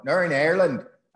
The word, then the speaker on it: Northern Ireland: My mother's family was Collins, and my stepfather's family, from which I have my family name, comes from Northern Ireland, or Nurin Ireland, as it's meant to be pronounced.